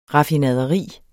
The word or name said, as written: RAFFINADERI